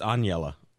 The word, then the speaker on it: Aniela: It's Aniela.